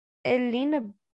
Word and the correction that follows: Helena: Bonham? – Yeah, Helena Bonham Carter. – Helena, that's it. Elena. I never know how to say her name.